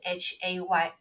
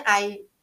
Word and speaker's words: hay: OK，那有这个字是 H A Y I，那 I 的 H 不发音，就是念 I A Y A Y 的音 I。 Donde hay 哪里有？所以你就可以问说，如果你今天想要，比如说你找不到，你要搭地铁站，结果你绕不到，你已经累死了，背那么重什么的，不方便找，你就问 Donde hay metro，estación de metro，